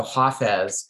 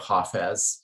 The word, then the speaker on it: Hafez: And so Hafez or Hafiz, depending on what language you say his name in, because people argue about how you pronounce the word, but of course, from Arabic, you know, across the Silk Road from the Arab world, from the Maghreb really to Pakistan, or to China, which was really the eastern end of the Muslim world, pronunciations change. And so in Farsi, one would say Hafez. In Urdu, which is in South Asia, one would say Hafiz.